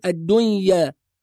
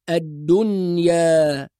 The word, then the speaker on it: الدنيا: وأهل التجويد يطلقوا مصطلح على هذا الإظهار فيقولوا إظهار مطلق، لذلك لو نظرنا إلى النون في كلمة الدنيا هنجد فوقها علامة السكون، فننطق النون ونظهرها بدون أي تحريك أو قلقلة أو إدغام، يعني لا نقول الدنيا ولا نقول الدنيا وإنما نقول الدنيا هكذا.